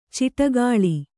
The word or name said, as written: ಚಿಟ್ಟುಕೋಳಿ